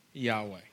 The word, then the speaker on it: Yahweh: And so the way to pronounce this name is, honestly, we don't really have a certainty of what it is. But our best guess is that it's pronounced Yahweh.